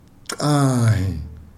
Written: xahhi

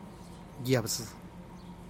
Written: yabdzï

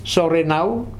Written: Sorernau

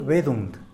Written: bedund

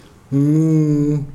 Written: nguu

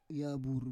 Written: yavur

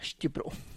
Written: xtyupru

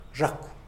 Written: zhakw

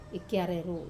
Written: kiare ru

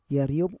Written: yariob

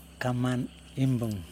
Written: kaman imbuN

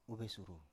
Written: uvesuru

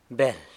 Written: beld

